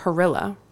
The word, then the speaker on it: Harilla: This interview was great, and it was written by our fall intern, Peter Harilla. He told me it rhymes with gorilla, so I knew how to say it correctly.